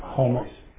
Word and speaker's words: homoousios: And the compromise was this idea that, how do you say that word? I would say it wrong every time. – Homoousios. – homo oestheus.